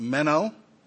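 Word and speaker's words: meno (μένω): I believe it's pronounced, the word is pronounced meno.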